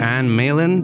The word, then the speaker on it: Anne Malin: We're here with Anne Malin.